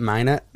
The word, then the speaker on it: Minot: And then another, a cop in that Minot, North Dakota. Am I saying that right? Minot. – Mm-hmm.